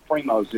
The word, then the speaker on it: Primos: It's Primos Hunting Calls is P-R-I-M-O-S. Primos is my given name, is the name of the company.